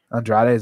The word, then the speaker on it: Andrade: About 3 minutes left in the first round with Shevchenko and Andrea. – Andrade, is that how you say it? – Yep.